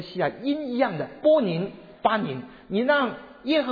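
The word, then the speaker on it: 波 宁 (בונים): 建造的人在希伯来文里面发音叫做波宁，波宁，波宁，这个发音啊，建造人的发音是波宁。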